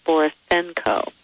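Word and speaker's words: Borysenko: So that's B as in boy, O-R-Y-S-E-N-K-O, phonetic, Borisenko. And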